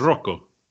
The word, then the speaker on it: Rocco: Are you pronouncing it as it would be pronounced in the Italian? – Yeah, I mean, Rocco's an Italian name.